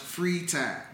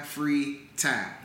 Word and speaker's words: Fritai: Alongside business partner Eva, he's co-owner of Free Thai, pronounced Free Thai, like free my man Thai, Free Thai.